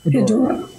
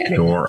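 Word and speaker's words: Hedorah: How do you pronounce? Is it Hedora or Hedora or what's the pronunciation of the Smog Monster? – Should be Hedora. – Hedora. Okay. Hedora.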